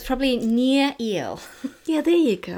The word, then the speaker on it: Nir: Is it ner or is it near? N I R. – It's N I R, but is it pronounced near? Because his blog is called Nir and Far.